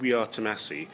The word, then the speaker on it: Tomasi: What they say is, the act is called We Are Tomasi, brackets rhymes with sassy, close brackets.